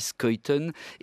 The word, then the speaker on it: Schuiten: Et français, le dessinateur François Schuiten, oui, on dit Schuiten, est belge.